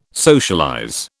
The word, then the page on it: socialize: British English